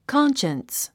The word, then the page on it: conscience: kάnʃəns; カンシャンス